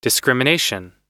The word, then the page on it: discrimination: American English